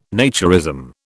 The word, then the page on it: naturism: British English